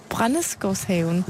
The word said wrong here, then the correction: Brændesgårdshaven: Ja. – Ej, det er ikke godt. Må hjem og øve mig.